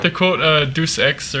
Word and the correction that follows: Deus Ex: or however you pronounce that. Deus-X. – Deus-X. – I always say Deuce, like it's 2X. It's the sequel to Deus Ex.